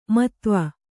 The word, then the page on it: ಮತ್ವ: kannada